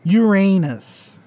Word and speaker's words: Uranus: Porque em inglês, caso vocês não saibam, a pronúncia do nome deste planeta é Uranus. E o que isso lembra? Literalmente, seu cu.